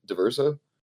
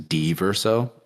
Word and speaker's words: Diverso: Did I say it right? Diverso? – Yeah, I give the D a lot of emphasis in D-verso, but the verso also sounds pretty dope.